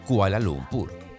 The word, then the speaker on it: Kuala Lumpur: Y Kuala Lumpur se pronuncia Kuala Lumpur.